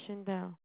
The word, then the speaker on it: Shaindel: My name is Shindel.